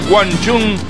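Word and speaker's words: Wang Chung: Así como lo escuchaste, Guanchum. Dance Halls Days. Esa es la pronunciación correcta.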